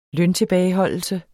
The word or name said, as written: løntilbageholdelse